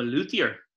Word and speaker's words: luthier: Is the pronunciation a luthier? – I'm a luthier. – A luthier, sorry. – Yeah. – Or a luthier, if you're from Dublin like you are.